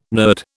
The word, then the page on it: nerd: British English